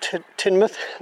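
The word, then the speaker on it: Tynemouth: I did some pictures of Tynmouth last week.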